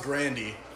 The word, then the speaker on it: Grande: Apparently it's pronounced Grandy.